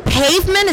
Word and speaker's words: Payvment: With me today is Christian Taylor with, is it Pavement? Is that exactly how you say it? All right, so that is P-A-Y-V-M-E-N-T.